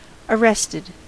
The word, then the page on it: arrested: American English